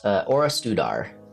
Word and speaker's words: Orastudar: It's Orastudar is the name of the city. I will type it into the chat. And it absolutely has a silent L.